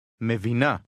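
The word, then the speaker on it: Mevina (מבינה): Mevina. – Repeat, part by part. – Na. Na. Vi. Vi. Vina. Vina. Me. Me. Mevina. Mevina.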